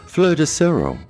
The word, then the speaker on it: Fleur de sureau: Elderflower in French is pronounced Fleur de sureau. Fleur meaning flower and sureau meaning elder, and is spelled F-L-E-U-R, Fleur, de, D-E, sureau, S-U-R-E-A-U.